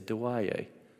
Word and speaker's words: Dawaye: And you saw in the video, I said, Jeffrey, how do you say thank you in Laguna? And he says, you say, Dawaye.